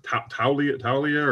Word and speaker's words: Taulia: How do you say his first Taulia or Tua's little brother? Let's just say that. – That's what I was going to say. That's how you pronounce it.